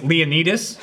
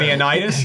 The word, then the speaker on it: Leonidas: It's all Greek pronunciation, so it's Achilles. Oh, okay. It's Leonidas, not Leonidas.